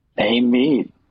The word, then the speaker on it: Amite: Amite. You said it correctly.